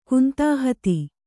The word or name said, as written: ಕುಂತಾಹತಿ